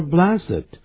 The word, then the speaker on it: blessed: Yeah, 我给你一部车子。In appreciation of your help, 感谢你对我的帮助。来this toy car, 这个还不错啊，这个小玩具车。Yes, let's say worth $2, 两块钱美金蛮好的。那在最后一行的时候特别记住,It is more blessed, 刚才 在念这个字的时候他不念成 blessed, 他念 blessed,因为它当个形容词用的时候代表呢有福气的意思啊，将来会得到好的报应的，我们就用 blessed 这个字。但如果当动词就念成 bless, 过去式是 blessed, 过去分词是 blessed.